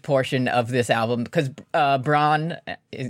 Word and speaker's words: Brann: And it's spelled B-R-A-N-N, right? – Absolutely. – But you don't say it like that. Now, here's where you're going to get a kick out of it. It's pronounced Braun.